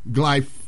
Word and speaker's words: glyphosate: Glyphosate, yes.